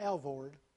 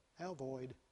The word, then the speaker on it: Alvord: Do you know you pronounce that right? Alvoid? Not Alvord. Alvoid.